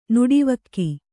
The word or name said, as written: ನಟವಿಗ